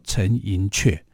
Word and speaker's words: 陈寅恪: 自为政的各，对，很多人都把它念成叫做陈寅恪，啊，那其实应该正确的读法要读作陈寅恪啊，因为他是客家人，然后这个恪字啊，在客家里面的读法，恪是读作阙啊，所以恪有两种音，一个读作克，一个读作阙，在这里呢，应该读作陈寅恪啊，这个部分比较少人知道了啊，所以我们应该啊为他做证明，因为他一直。